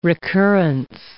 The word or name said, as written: recurrence